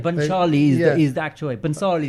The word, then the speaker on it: Bhansali: You just said Bhansali. It's hard to say. – But that's how it's said. Bhansali.